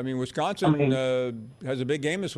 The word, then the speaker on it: Wisconsin: Wisconsin. They're gonna. – It's Wisconsin, not Wisconsin. – Wisconsin.